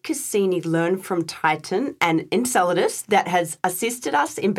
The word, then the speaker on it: Enceladus: and I might need you to correct my pronunciation on that one. But it's Enceladus. – Enceladus. Thank you. I had a feeling I might have said that the wrong way.